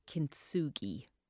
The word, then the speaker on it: kintsugi: And where does kintsugi come from? – Yes, that's pretty good. Kintsugi. It's actually kin means gold and tsugi means to join. So it's kintsugi, a gold joining.